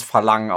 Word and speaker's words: Farang: Also der Film heißt, also er wird Farang geschrieben, aber unser Moderator hat uns mehrfach darauf hingewiesen, dass es Verlang ausgesprochen wird. Deswegen sage ich jetzt Verlang.